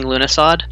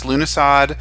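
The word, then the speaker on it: Lughnasadh: I have to say thank you for pronouncing Lunasad. – Oh. – I've never heard it pronounced. – Oh, well, it's actually pronounced Lunasad, Lunasa, and if you are local out here and are hilarious and know our friend Elia, Lugnasadad.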